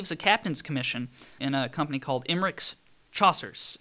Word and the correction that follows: chasseur: or chasseur, I guess is a more accurate pronunciation.